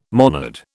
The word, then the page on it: monad: British English